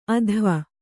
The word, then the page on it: ಅಧ್ವ: adhva